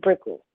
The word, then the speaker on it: Brickell: Brickell is, that's how I pronounce it, Brickell. You know, my accent.